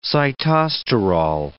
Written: sitosterol

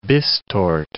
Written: bistort

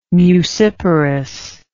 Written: muciparous

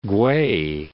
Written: Kuei

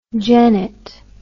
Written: gennet

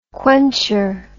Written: quencher

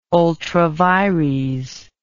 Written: ultra vires

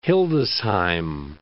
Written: Hildesheim

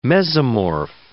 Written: mesomorph

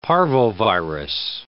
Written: parvovirus

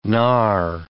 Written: gnarr

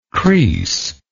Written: creese

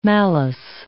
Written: malice

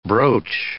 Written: brooch

